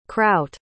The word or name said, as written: crout